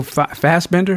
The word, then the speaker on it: Fassbender: The android aspect of the movie, Michael Fassbender, if I'm saying his name right? – Yes.